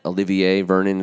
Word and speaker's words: Olivier Vernon: we need an Olivier Vernon, is that how you pronounce his name? – Yes.